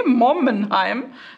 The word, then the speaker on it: Mommenheim: Okay, Mommenheim. Habe ich das richtig ausgesprochen? Ja. – Ja, ja.